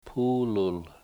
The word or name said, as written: puulul